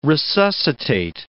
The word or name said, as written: resuscitate